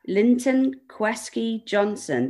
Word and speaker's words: Linton Kwesi Johnson: Your next song choice, Linton Kwesi Johnson, The Iron Bar Derb.